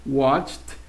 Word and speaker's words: watched: Watch, watched, watched. – Como una pronunciación en T al final.